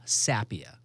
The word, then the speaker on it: Sapia: But Paul Sapia.